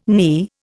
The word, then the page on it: knee: Indian